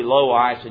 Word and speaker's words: Lois: Now I know some of you are out there with your self-help pronunciation Bible saying, "Preacher, that's really low ice and you nicey." I know it is, but we're Southerners here, so we're going to say Lois and Eunice, all right? That's just how we pronounce it down here.